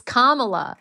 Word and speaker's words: Kamala: Because I think it's funny that it's like, even when she's pronouncing it, she'll be like, it's Kamala.